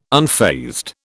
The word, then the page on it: unfazed: British English